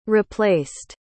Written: replaced

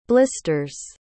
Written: blisters

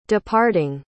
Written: departing

